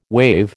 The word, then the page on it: waive: American English